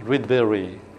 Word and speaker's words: Rydberg: Now, a few years later, a Swedish, Rydberg, The Swedish is pronounced Rydberg. So, since we are English, using English to say Rydberg, okay, he generalizes, generalizes See here?